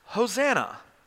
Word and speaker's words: Hosanna: And the Hebrew here, the construction of this phrase would have been pronounced Hosanna.